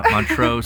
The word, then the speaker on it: Montreux: Like Montreux. I'm not pronouncing it right. Not Montreal, Canada. Montreux, Switzerland, home of the Jazz Festival, coming to you soon.